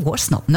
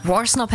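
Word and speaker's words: Worsnop: Ist das richtig ausgesprochen? Warsnop, ne? – Warsnop hätte ich jetzt auch gesagt, aber ich finde diesen Anfang so mega.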